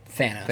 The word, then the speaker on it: Thanos: Thanos.